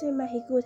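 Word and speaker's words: México: البته مکزیک را به شوخی و لهجه اسپانیایی به صورت مهیگو تلفظ کرد.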